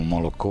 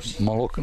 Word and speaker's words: Moloko: Ona je, mi kažemo Moloko, u stvari bi trebalo se kaže Moloko, ne, kako se na ruskom zaista kaže, izgovara mleko. Jel zna neko ruski ovde? Nije Moloko, to je naš akcenat.